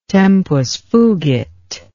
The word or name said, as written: tempus fugit